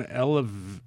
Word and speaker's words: Elavil: Following up on the Elavil discussion, I have been on Elavil for migraines for over 15 years.